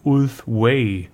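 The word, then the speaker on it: Ulthwé: Heute erst gelernt, es wird wohl Ulthway, so wie der Weg im Englischen, ausgesprochen.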